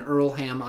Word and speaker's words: Earlham: We are currently in Earlham, Iowa. Sorry, the H is silent. Earlham, Iowa.